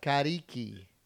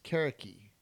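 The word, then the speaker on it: Carriqui: Kariki.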